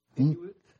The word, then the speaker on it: Inuit: She was a new Hewitt, or how do you say the last name? – Inuit? – Yeah.